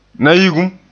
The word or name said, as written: nayigum